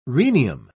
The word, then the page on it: rhenium: British English